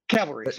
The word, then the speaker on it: cavalry: So I wouldn't trust that the Calvary is coming. – I'm not trusting the Calvary is coming. – Yeah. Cavalry. I mispronounced it.